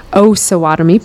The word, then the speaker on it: Osawatomie: Tonight we are diving into, I finally found out how to say this correctly, the Osawatomie Pound. It's been heard of as the Oz Pound, so that's where we got the miscommunication from. Thank you, Jen, for that. So now we know how to say it correctly going forward is Osawatomie Pound.